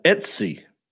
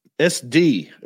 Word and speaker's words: Etsy: Yeah, Etsy. – Etsy? – You were saying... SD at one point.